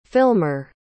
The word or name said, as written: filmer